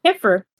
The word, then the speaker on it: camphor: The website I go to get a lot of the scans, Camp Horror Tree, however it's pronounced. I just know Camphor Tree. – Camphor. – Okay. It's camphor? – Yeah.